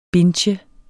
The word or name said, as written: bintje